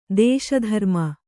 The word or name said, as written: ದೇಶಧರ್ಮ